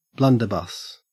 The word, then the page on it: blunderbuss: Australian English